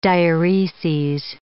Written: diureses

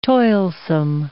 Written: toilsome

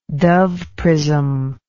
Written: Dove prism

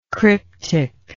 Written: cryptically

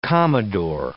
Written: commodore

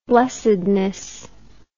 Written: blessedness

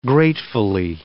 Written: gratefully

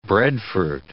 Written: breadfruit

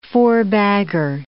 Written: four-bagger

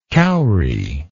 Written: cowrie

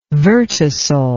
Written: vertisol

